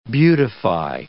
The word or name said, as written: beautify